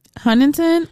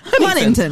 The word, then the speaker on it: Huntington: Huntington. – Huntington. – Huntington. Huntington Beach. Huntington. – Your T is there at the end, baby. You got it.